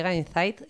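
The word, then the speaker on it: Incyte: Se escribe con Y, Insight, y entiendo que se pronuncia. – Oh, Insight, qué nivel. Insight suena a... Es Incite, con Y, la segunda.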